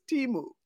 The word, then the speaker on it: Temu: I had to check a couple of videos to see how are they pronouncing this. Oh yeah, they're pronouncing this Temu.